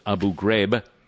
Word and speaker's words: Abu Ghraib: He mispronounced Abu Ghraib each of the three times he mentioned it. The name is usually pronounced by English speakers as Abu Ghraib.